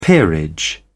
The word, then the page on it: peerage: British English